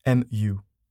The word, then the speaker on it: Mu: And it is, pronunciation will be quite, I guess, difficult for people, but I think the right one is Mu.